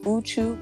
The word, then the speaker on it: Uchū: and Uchu Botanical, established in 2018.